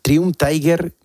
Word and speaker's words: Triumph Tiger: en una Triumph Tiger 1200. ¿La he pronunciado bien el nombre? – Sí, perfecto. – Triumph Tiger, ¿esta moto cómo es? – Es grande.